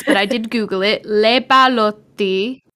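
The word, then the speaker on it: Leppalúði: That's phonetically how it told me on Wikipedia, but it's spelt like L-E-P-P-A-L-U with a little thingy on it. Something that looks kind of like an O and then an I, but it's pronounced Le Balotti.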